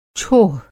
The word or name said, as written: छोह